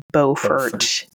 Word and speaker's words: Beaufort: And I know you do pronounce Beaufort, South Carolina as Beaufort, even though it looks like it should be Beaufort. It's Beaufort.